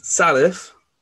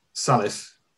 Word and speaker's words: Salif: So it's Salif. – Salif. – And then this is the bit I'm getting confused on. It's Diao. It's Diao. – Diao. Salif Diao.